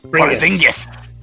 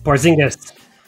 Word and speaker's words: Porzingis: Kristaps Porzingis. – Porzingis.